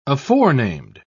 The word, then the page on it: aforenamed: British English